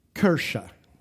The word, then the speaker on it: Kirche: Kirche. It's spelled K-I-R-C-H-E.